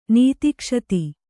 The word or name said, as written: ನೀತಿಕ್ಷತಿ